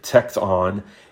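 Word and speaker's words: tekton (τέκτων): Truthfully, this word, it's pronounced tekton, it's used of people that we might think of today as a general contractor.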